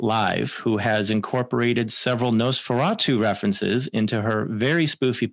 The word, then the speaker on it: Nos4A2: Also, very recently, we've had the television series Nos4A2, pronounced Nosferatu, which screened for 2 seasons on AMC, starring Ashley Cummings and Zachary Quinto.